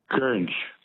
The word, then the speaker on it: Goringe: The word that rhymes with orange is gurnge. – Is what? Gurnge? – Gurnge, yeah. – What's the spelling of gurnge? – G-O-R-I-N-G-E.